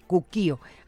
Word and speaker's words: Cuquío: Acatlán y Cuquío, con acento en la I. Cuquío, así se llama,